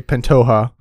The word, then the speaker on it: Pantoja: And that is the champion in Brandon Moreno versus number 2 ranked Alexandre Pantoja.